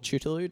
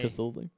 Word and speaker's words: Cthulhu: The Call of Cthulhu, or Chutulu, depending how you pronounce it. I say Cthulhu.